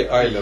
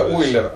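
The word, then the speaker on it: Euler: Я гадаю, все ж таки Айлер был, нет? – Я думаю, что... – Сетево именно... Ойлер, так? А, Эулер пишется, так? Это Ойлер, ага.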